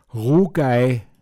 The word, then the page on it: Ruamzuzla: Pinzgauer Mundart